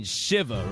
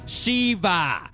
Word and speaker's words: Shiva: Oh, you mean Shiva, right? Oh my god, do not pronounce it like that. It's pronounced Shiva. Shiva.